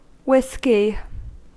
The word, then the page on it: whisky: American English